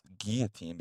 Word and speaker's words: guillotine: and it's pronounced guillotine because it's originated in French.